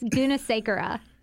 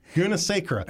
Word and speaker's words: Gunasekara: Did I say it right? No, I didn't. Did I? – It was really close. Gunasekara. – Gunasekara.